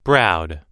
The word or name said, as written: browed